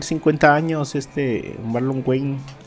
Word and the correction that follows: Wayans: Wayans. ¿Cómo se dice? Wyans. – Wyans. Wyans, creo.